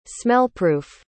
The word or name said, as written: smellproof